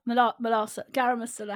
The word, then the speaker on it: garam masala: Garam masala.